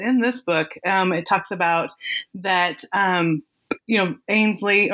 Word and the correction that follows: Ansley: – or how do you say it? Is it Ainsley or Ansley? – It's Ansley.